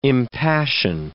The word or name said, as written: impassion